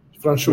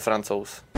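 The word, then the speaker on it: Francouz: Franchos. – Franchos. Det är det tjeckiska uttalet. Mm. – Frankie kallar de honom internt i laget. Ja, det kanske är lättare. – My name is uh, Pavel Franzos.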